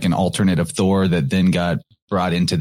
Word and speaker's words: Thor: We're about to drop the hammer, but Thor, or as you correctly mentioned, Tor or Tar, are other ways of pronouncing this name.